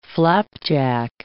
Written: flapjack

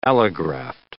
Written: allograft

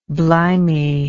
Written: blimey

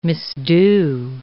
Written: misdo

